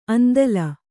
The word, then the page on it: ಆಂದೋಲ: āndōla